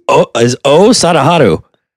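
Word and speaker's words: Oh Sadaharu: his name is O Sadaharu.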